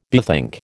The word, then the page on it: bethink: American English